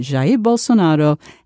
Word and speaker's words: Jair Bolsonaro: And finally, how Brazilian women are responding to the election of President Jair Bolsonaro, an unrepentant, misogynist, racist autocrat. – What a great pronunciation. – Wow. Jair Bolsonaro.